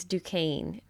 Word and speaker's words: Duquesne: When Kathleen and I were first discussing this, and I kept talking about Duquesne, and finally Kathleen was like, it's Duquesne.